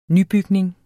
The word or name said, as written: nybygning